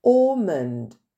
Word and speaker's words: almond: Almond. Le L ne se prononce pas.